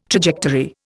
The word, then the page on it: trajectory: Indian